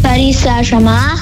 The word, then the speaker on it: Paris Saint-Germain: Ich bin ein Laufkind in der Champions League im Halbfinale gegen Paris Saint-Germain.